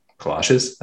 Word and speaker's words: kolaches: That has the best kolaches Kalaches. That's how you say it.